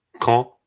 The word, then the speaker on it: Caen: You guys could look up Kane, C-A-E-N, from 2016, the French Normandy squad. – Caen.